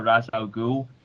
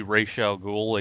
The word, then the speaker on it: Ra's al Ghul: So, I had Nightwing kidnapped by what was supposed to be Ra's al Ghul in quotations. – Ra's al Ghul. I'm using the movie pronunciation, sorry.